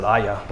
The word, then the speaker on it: Ai: So last week we did Jericho, and this week the place is called I, but it's spelled AI, so I don't know how I'll say it. Some people say you're supposed to pronounce it Aya, but definitely not doing that, you know.